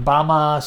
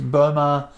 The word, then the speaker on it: Burma: Is basically, sometimes it's pronounced Bama, sometimes it's Burma. It depends a little bit on where you are within that mm-hmm.